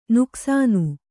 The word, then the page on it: ನುಕಸಾನು: nukasānu